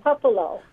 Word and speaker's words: Puppolo: It's Linda Puppolo.